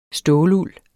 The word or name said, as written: ståluld